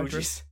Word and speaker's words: Fugees: Fuji. – Does it not qualify? – The Fugees. – Yeah. Fugees? Is that how you say it? Oh. – The Fugees. – I assumed it was like Refugees.